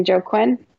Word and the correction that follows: Joaquin: Oh, uh Joaquin Buckley.